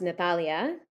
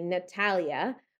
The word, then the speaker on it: Natalia: my name is pronounced Natalia. And, you know, a lot of people can't say that because they don't, English language doesn't have that sound T in it. So they will say Natalia, which I avoid saying my name because it just feels weird for me to say it that way because that's not my name.